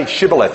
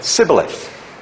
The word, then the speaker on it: Shibboleth: But those of the dialect from Ephraim couldn't say Shibboleth. They couldn't pronounce sh. They could only say s. So they said Sibboleth.